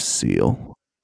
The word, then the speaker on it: Seel: It sounds like a seal, and its name is Seel. yes, E. Spell it wrong. That's all they do. – It sounds like this. – That's Seel. all they did was spell it wrong.